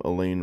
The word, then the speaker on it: Elaine: Even in the lunchroom, Elaine rhymes with pain.